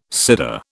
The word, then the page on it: Seder: British English